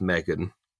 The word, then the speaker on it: M3GAN: So this is Megan, stylized to be M3GAN. It's pronounced Megan.